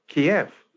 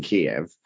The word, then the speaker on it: Kiev: There seems to be a lot of confusion about the mispronunciation of Kiev. – It, well, there's the Russian known... pronunciation, which is like chicken Kiev, and then there's Kiev, which is actually the Ukrainian for the capital.